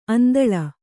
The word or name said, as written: ಆಂದೋಳ